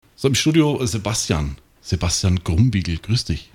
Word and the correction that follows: Krumbiegel: Habe ich das richtig ausgesprochen? – Ich glaube, ihr Franken, ihr habt manchmal ein bisschen mit K und G ein Problem. – Also Krumm. – Genau, Krumbiegel. Das wird dann, ihr Franken rollt das R, das kann ich nicht ganz so gut.